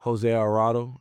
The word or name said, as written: Jose Alvarado